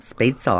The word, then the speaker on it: Spätzle: And I just love the one thing on the side, there's spätzle. – That is, how do you pronounce that? Spätzle? Spätzle? – Spätzle, I always say.